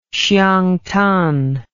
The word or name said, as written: xiangtan